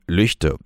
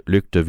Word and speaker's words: Lügde: Und da geht es um den Skandal von Lüchte oder beziehungsweise Lügde, wie auch überregional dieser Ort hier in Nordrhein-Westfalen ausgesprochen wird.